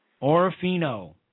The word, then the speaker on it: Orofino: A little town called Orofino. – Orofino. Did I pronounce that right? – Yes.